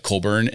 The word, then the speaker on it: Colborn: So my last name is Colborn, and it gets regularly spelled wrong by everybody that I know. I think because like we tend to say it like Colburn, and so people spell B-U-R-N.